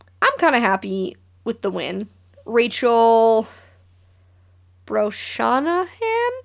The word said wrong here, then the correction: Brosnahan: I'm gonna pronounce that wrong. That's cool. – Brosnahan.